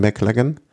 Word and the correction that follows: Lachlan Morton: Weißt du, was äh, äh, Lachlan Morton. – Ja, genau, Lachlan Morton.